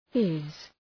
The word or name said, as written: fizz